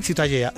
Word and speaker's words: Ciutadella: ¿cómo se pronuncia? – La catalán? Ciutadella. – Ciutadella. Bueno, pues Ciudadela también en castellano. – Ciudadela, sí. Bueno, pues eso, ha quedado clarísimo. Pero, ¿para qué se meten? – ¿Tú cómo lo pronuncias? – Ciutadella. – Muy bien. – Bien, ¿no? En euskera, Citadela o Gotorleku.